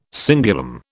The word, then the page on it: cingulum: British English